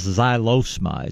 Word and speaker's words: Xylosma: But there's a shrub out there called Xylosma. It's spelled like xylophone, X-Y-L-O-S-M-A.